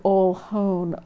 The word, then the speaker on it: Oljon: The most likely origin of the word Ohlone is the word Oljon, O-L-J-O-N, pronounced with Spanish vowel and consonant pronunciation, because it was the Spanish who first wrote down the tribal names.